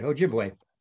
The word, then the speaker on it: Ojibwe: And I had to find out how to pronounce this. So it's a part Irish and part, don't say it, Ojibwe, right? – You're pretty good. Ojibwe.